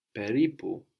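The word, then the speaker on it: peripu (περίπου): Around about or approximately is peripu, peripu. – Peripu. – So we have peri, no, and pu, peripu.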